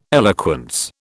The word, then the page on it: eloquence: British English